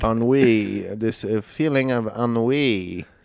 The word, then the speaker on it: ennui: Uni.